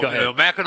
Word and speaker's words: Mackinac: When he goes to Mackinac Island? – Oh, no, Mackinac Island. – Mackinac Island. – First of all, yeah. – It's spelled, why do they spell it Mackinac when it's Mackinac?